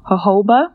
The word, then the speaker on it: jojoba: The next option for essential oil that I like to use is jojoba. It's spelled J-O-J-O-B-A. It's Spanish, so that is how it's actually pronounced.